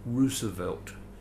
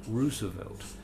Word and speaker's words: Roosevelt: You know, they want to say Roosevelt, President Roosevelt. No, it's Roosevelt. It's heavy sort of Dutch pronunciation.